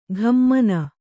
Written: ಘಮ್ಮನ

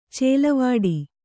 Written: ಚಲವಿಡಿ

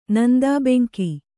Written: ನಂದಾಬೆಂಕಿ

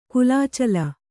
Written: ಕುಲಾಚಲ

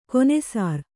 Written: ಘನಸಾರ

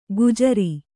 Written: ಗುಜರಿ

